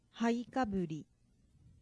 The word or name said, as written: haikaburi